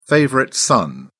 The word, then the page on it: favorite son: British English